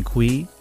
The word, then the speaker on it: Cui: Lastly, you can find me, Brian Cui, last name is spelled C U I, on LinkedIn.